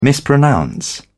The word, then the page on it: mispronounce: British English